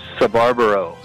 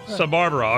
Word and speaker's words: Sbarbaro: Sbarbaro. – Sbarbaro.